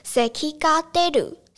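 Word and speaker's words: 咳が出る: 然后如果你要说有咳嗽这个症状的话，你可以搭配一个动词叫做 seki ga deru。 seki ga deru 就是有咳嗽这个症状可以搭配的动词，然后第二个叫做肌肉酸痛，kinniku tsu，kinniku tsu，汉字是筋肉痛。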